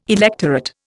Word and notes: electorate: Indian

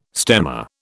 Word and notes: stemma: British English

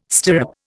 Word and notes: stirrup: Indian

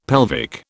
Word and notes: pelvic: British English

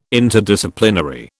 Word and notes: Interdisciplinary: British English

